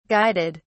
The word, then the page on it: guided: British English